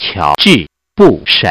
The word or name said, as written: bush